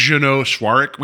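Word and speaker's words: Jeannot Szwarc: So it's Jeanot Silzark. – I think it's Jeanot Swarik.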